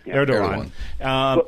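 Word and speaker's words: Erdoğan: You know, what role would Turkey play, especially under, I don't know if I say this right, Erdogan? – Erdogan.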